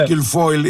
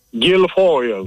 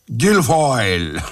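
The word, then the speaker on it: Guilfoyle: Για πείτε μου το όνομα Κυλφόιλ, έχετε μια ειδική έτσι προφορά. – Γκυλφόιλ, όπως λέτε κι εσείς. Γκυλφόιλ.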